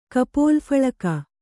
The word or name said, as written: ಕಪೋಲಫಲಕ